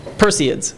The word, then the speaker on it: Perseids: Perseids, because it comes from the constellation of Perseus.